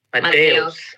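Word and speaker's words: Mateus: How do you say Mateo in Portuguese? – Mateos. – Mateos? With an S? – Yep. – Mateos.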